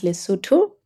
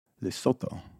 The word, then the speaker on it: Lesotho: You are a writer, a voiceover artist, clearly, and a radio host, and you are based in Lesotho, Southern Africa. Is that correct? – That is correct. It's pronounced Lesotho, but... – Lesotho.